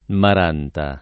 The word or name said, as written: Maranta